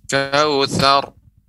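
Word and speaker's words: Kawthar (كوثر): Inna a'tayna kal kawthar. – Kawthar. sar. Langsung pengucapan ra tapi ra-nya tebal. Kawthar.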